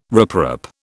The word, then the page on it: riprap: British English